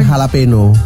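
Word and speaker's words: jalapeño: Cheetos Crunchy Cheddar Jalapeno. Jalapeno. – Hah? Bacanya itu ya? – Iya, jalapeno. – Kok tulisannya kan jala? – Kan kalau orang Meksiko, orang Latin ngomongnya gitu. J jadi H.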